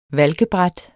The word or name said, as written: valkebræt